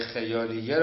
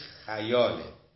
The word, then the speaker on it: خیال: آیا در واقع صور خیالیه را، البته ما فارسی میگیم خیال، صحیح ضبط عربیش خیاله، خیال فتحه داره، ولی برای کثرت استعمال ما همون خیال داریم. تلفظ صحیح ادبیش خیاله.